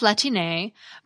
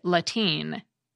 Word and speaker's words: Latine: Similar terms have popped up in English, like mix, MX, as a courtesy title, and folks, F-O-L-X, as a way to refer to commonly marginalized people. We also have Latine, but some English speakers may pronounce it Latine. Some people prefer Latine because it fits better with Spanish rules of pronunciation.